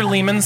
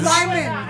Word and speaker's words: Lyman: My favorite Arthur Lehman song is Lyman.